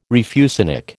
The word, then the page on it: refusenik: American English